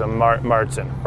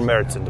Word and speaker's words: Märzen: Most people are used to what is quote unquote Oktoberfest beer, which is a Märzen or Märzen, depending on how you like to pronounce it.